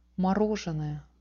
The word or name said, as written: morozhenoe